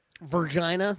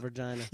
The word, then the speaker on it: Vergina: It is spelled V-E-R-G-I-N-A. – Virginia? – Virginia. The son of Virginia.